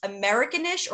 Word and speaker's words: Americanish: Okay, so I watched, is it pronounced Americanish or Americanish? – Americanish. Yeah, just Americanish. – American-ish. Just like Blackish.